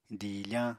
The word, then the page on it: diifañ: Apatani